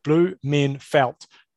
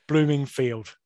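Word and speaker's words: Blummenfelt: Bevan needs to learn to pronounce Blumenfelt's name. He's an Olympic champion and now an Ironman world champion. Blumenfelt, not Bloomingfield.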